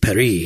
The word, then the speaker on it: Perry: Like the band Perry? – Well, I was like Paris, but pronounced Perry.